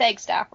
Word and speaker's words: Eggstaff: It's Eggstaff, right? – Yes, it's Johnathan Eggstaff. It's spelled exactly how it sounds.